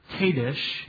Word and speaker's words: kadosh: The root meaning of the Hebrew noun holiness is pronounced kadosh in Hebrew.